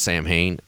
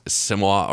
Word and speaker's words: Samhain: which is not actually pronounced Samhain. Actually, it's Samhain or something like that.